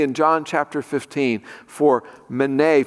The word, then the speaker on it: menei: And it's the Greek word mene, M-E-N-E-I, mene is how I pronounce it.